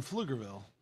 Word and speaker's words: Pflugerville: The place I grew up, the name of the city has a silent P. – And so where did you grow up? – I'm from Pflugerville, but it says PFL.